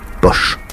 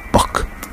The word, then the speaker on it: Bosch: Car Jérôme Bosch peut se prononcer Bosch ou Bock.